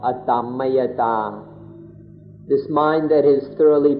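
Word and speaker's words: atammayatā: So please be patient and learn the Pali word adhammayata. Adhammayata, A-T-A-M-M-A-Y-A-T, long A, adhammayata.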